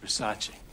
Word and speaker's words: Versace: Versace. It's pronounced Versace.